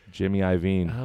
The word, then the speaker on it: Iovine: Shout out to Gruber, who always mispronounces his name. Gruber, I love you, but it's pronounced Jimmy Iovine.